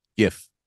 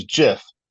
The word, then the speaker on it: GIF: It said he called it a GIF with a soft G. Choosy developers, he said, choose GIF.